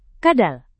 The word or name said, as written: kadal